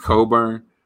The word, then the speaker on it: Cockburn: There's a guy named Kofi Coburn, and it's spelled like Cockburn, which is sick, but it's pronounced pronounced Coburn.